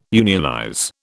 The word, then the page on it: unionize: British English